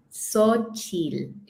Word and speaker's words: Xochitl: Actually, I do want to ask you, how do you pronounce it? Just because I like to actually try and get it the right way. – Sochil. Kind of like Korean vowels are closer.